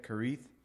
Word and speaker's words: Kerith: And that is how you say it, Kerith, the Hebrew.